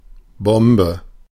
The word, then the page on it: Bombe: /ˈbɔmbə/